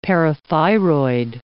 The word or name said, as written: parathyroid